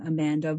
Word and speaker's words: Amandha: And that is under my name, Amandha Vollmer, and Yum Naturals Emporium. So if you look up either my name, which is spelled with an H, A-M-A-N-D-H-A, Vollmer, V-O-L-L-M-E-R, and then Yum Naturals, you'll find me on there.